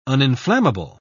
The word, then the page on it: uninflammable: British English